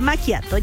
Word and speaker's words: macchiato: No, sí, pero es macchiato, ya.